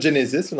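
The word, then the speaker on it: Genisys: Dans le film, il prononce ça Genesis dans le film.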